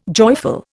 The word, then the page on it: joyful: Indian